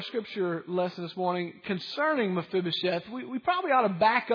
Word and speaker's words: Mephibosheth: You already knew how to pronounce that, Mephibosheth.